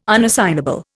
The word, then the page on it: unassignable: Indian